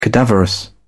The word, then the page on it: cadaverous: British English